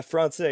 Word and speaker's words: Français: Français. Am I saying that right? – Okay, let's go. Yeah, Français, exactly.